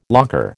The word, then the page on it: locker: American English